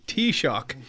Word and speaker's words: Taoiseach: Taoiseach.